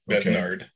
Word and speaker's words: Besnard: How do you spell that? Besnard? – B-E-S-N-A-R-D. – Besnard. – Besnard okay.